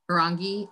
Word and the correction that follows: hiragana: I maybe pronounced hiragana. That. Hiragana. – Hiragana, yes, there we go.